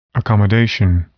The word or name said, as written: accommodation